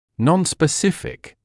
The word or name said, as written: non-specific